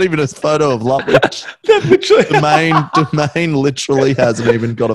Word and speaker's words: Lutwyche: For the record, it's Lutwyche, so it's L-U-T dash W-I-T-C-H.